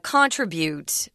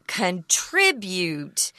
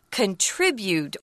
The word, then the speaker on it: contribute: I've taught a lot of business people here in Taiwan, and everyone wants to say contribute, and it's wrong. So the verb is contribute. The noun is contribution, which is kind of hard because it just makes it even more difficult to remember the verb's pronunciation, but it's contribute.